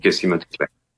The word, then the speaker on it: Casey Mottet Klein: Son nom est Casey, je prononce mal, là, Casey Mottet Klein. – Oui, c'est ça. – OK. – Casey Mottet Klein.